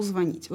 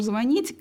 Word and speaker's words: звонит: Потом, видимо, большая актуальность приобрели орфоэпические нормы во время подготовки к ЕГЭ, и дети уже знают, почему нельзя говорить «звонит», почему нужно говорить «звонит», и это можно объяснить правилами, орфоэпическими правилами в словаре.